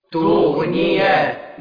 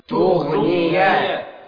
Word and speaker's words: تغني: خلي بالك تغنية. تغنية تتنطق إزاي؟ – تغنية. – مرة تانية كمان. – تغنية. – هكذا بالغين، لأن البعض يقلب الغين إلى خاء.